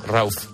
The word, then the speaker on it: Rauw: Es R-A-U-W. – Sí, ¿y no se dice Rauf? – No, Rauf. No sé. – Rauf. Es que ese nombre siempre ha existido. Mi madre siempre lo ha dicho así. – Pero es Rauf. Alemán, ¿no?